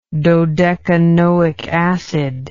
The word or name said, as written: dodecanoic acid